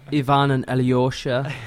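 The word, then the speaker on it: Ivan & Alyosha: Pretty sure you pronounced that wrong. – Ivan and Alyosha. – There you go.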